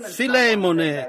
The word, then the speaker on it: Filemone: Così Filemone, ha detto qualcuno lì in fondo, il che penso ne sia realmente la pronuncia corretta.